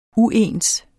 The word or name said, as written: uens